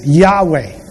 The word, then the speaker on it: Yahweh: It's YHWH, most commonly pronounced as Yahweh.